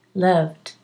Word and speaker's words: loved: loved is a D, D, loved, but you can say loved, with a T. It's okay.